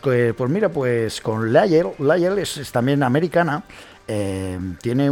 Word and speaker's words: Lyell: Y bueno, pues ella, Lyle, se llama, se escribe L-Y-E-L-L, Lyle.